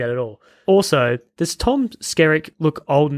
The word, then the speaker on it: Skerritt: So we see Tom Sirzik. How do you say his name? – Tom Skerritt.